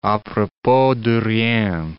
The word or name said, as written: a propos de rien